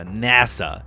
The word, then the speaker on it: NASA: 呃，那就是美国的这个呃，张浩哲给我纠正了发音，一定要用美式的方法念，叫 NASA。